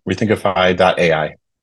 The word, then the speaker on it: Rethinkify: Rethinkify.ai.